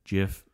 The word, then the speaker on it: GIF: GIF, that J sound is the correct one.